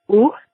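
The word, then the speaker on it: eau: It's au micellar. So the O sound in French is kind of like the O in a word like cold. It's au, au.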